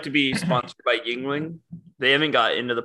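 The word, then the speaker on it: Yuengling: You're adding an extra syllable. Just Yingling.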